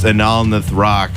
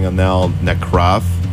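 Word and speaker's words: Anal Nathrakh: It's Anal Nathrock, and I think I've been saying Anal Nekroth, whatever.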